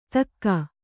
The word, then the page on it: ಧಕ್ಕಾ: dhakkā